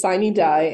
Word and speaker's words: sine die: Yes, it is sine die, and it is the end of the current legislative year.